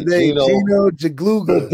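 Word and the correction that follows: Guidugli: Gadula. No, Gadula.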